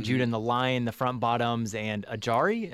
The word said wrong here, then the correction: AJR: Actually, am I pronouncing it? AJR.